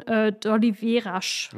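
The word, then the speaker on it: d'Oliveiras: Ein Tarantez von 1890, bottled 2024 von d'Oliveras. – Ja. – Wird so ausgesprochen? Ja. – Ferreira d'Oliveras, ja.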